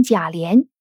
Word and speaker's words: 贾琏: 所以，为什么我们说把贾琏的名字读成贾琏也是正确的？因为这个“ 脸” 字在古代它就通“ 连” 字。